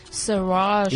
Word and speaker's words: Saoirse: Yeah, please pronounce that for me because I'm looking in the handbook here or the pamphlet from the show. Saoirse. Did I say it right? – That's right.